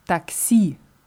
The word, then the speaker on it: такси: Ja, nur die Betonung ist anders. Ist Taxi.